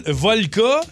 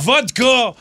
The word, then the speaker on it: vodka: Et quand je comprends un Red Bull Volca, c'est pas de même, ça se dit. – Volca! – Vodka! Eh ouais.